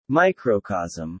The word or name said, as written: microcosm